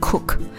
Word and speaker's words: CUC: 之前可能有听我聊到哦，古巴有两种钱，一种叫 CUP，一种叫 CUC，我们发音就是 coup 跟 cook，其实这两个真的很相近啊。那所以每次在古巴都要非常明确的去跟对方确认，你跟我报的价是 cook 还是 coup。